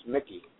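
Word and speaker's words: Michie: Carolina Yakfish Tourney will be in North Durham at Lake Michi. Oh, pronounced Mickey.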